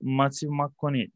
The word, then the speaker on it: Matthew McConaughey: Ya şöyle True Detective'i izledim ama ben orada daha çok Matthew McConaughey değil mi? Adı böyle okunuyor.